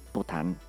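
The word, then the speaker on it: poutine: el Pután. No, es que hasta hay memes porque se, parece, o sea, se escribe como Putin, como Vladimir.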